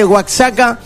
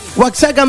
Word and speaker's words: Oaxaca: Oaxaca, dicen. – Oaxaca, sí. – Oaxaca, le dicen. – Oaxaca, ellos. – No sé. – No, se escribe siempre en Oaxaca, como Texas, Texas.